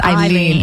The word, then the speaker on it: Aileen: I've heard her, even though her name is spelled Aileen, it's pronounced Aileen.